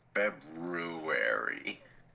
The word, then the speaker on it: February: I enjoy how nobody pronounces the second R in February. February.